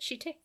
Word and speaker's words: shite: Everyone has a shite. Is that how you can pronounce it? Shite? – Shite, I would say, yeah.